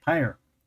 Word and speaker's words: Pyr: P-Y-R? – Pyre.